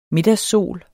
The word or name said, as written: middagssol